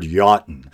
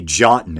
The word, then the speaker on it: Jotun: Now, I know that in Old Norse, German, Swedish, etc., the letter J is usually pronounced the way we pronounce the letter Y, as in, you know, yes or yellow. And I don't mispronounce other names or words from Norse mythology, like Mjolnir or Jormungandr. Probably still kind of butchering them, but at least I got the J pronunciation part right. But for some reason, I tend to say Jotun instead of Jotun.